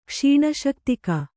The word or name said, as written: ಕ್ಷೀಣಶಕ್ತಿಕ